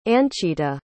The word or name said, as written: Anchita